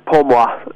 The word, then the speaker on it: Pour Moi: Chatting to Pour Moi. Did I say that right? Is that correct? – Yeah, Pour Moi. Yeah, means for me in French.